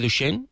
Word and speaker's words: Duchenne: distrofia muscular de Duchenne, ¿es así? – Sí, correcto, correcto.